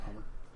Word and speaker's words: Humber: No, I'm going to call it Hummer. – Hummer. I mean, am I sure? – It's Humber. – I know. Yeah, well, it's British. The B is silent.